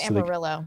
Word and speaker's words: Amarillo: Is it Amarillo or Amarillo in Texas? – Amarillo. So they go with the American bastardization of the Spanish pronunciation.